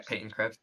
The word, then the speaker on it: Peyton Krebs: Peyton Krebs. – Yeah, that's how you say it.